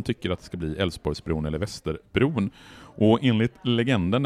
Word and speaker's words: Älvsborgsbron: Och den här lilla pojken säger Älvsborgsbron och då inser Torsten Henriksson att det göteborgarna kommer göra, det är att de hoppar över de här besvärliga konsonanterna i uttalet av Älvsborgsbron. Så det blir Älvsborgsbron som man beslutar att den här bron ska heta 1961.